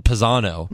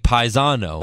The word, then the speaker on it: Pisano: Like, my name's, in America, you pronounce it Pisano. – Mm-hmm. – But if you were, like, in Italy, it'd be Paisano.